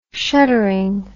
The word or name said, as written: shuttering